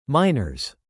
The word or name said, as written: minors